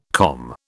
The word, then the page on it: COM: British English